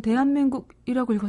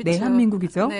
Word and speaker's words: 내한민국: 대한민국을 잘못 읽었나? 하셨을 것 같은데. 네. 사실 저도 처음에 이제 습관적으로 책을 받아보고 아, 스무 살에 몰랐던 대한민국이라고 읽었어요. 근데 정확히 아니죠. – 그쵸. 내 한민국이죠. 네. – 나의 내 한민국이죠. 나의 한민국.